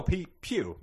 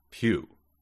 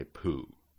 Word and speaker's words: Pugh: It is P-U-G-H. – Oh, Pew, my mom's last name. – Pew. – Yes. – Because I would say poo.